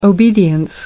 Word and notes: obedience: American English

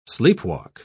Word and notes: sleepwalk: British English

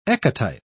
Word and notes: ecotype: British English

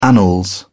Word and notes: annals: British English

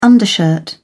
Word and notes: undershirt: British English

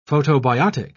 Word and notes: photobiotic: British English

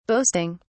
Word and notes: boasting: British English

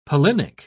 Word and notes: pollinic: British English

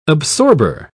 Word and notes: absorber: British English

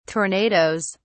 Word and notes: tornadoes: British English

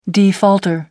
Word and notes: defaulter: American English

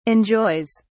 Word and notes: enjoys: British English

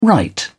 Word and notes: write in: British English

